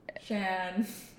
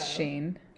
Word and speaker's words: Shein: Because the people will be like Shein, Shan. I'm like, no. – It's Shein. – It's fucking Shein.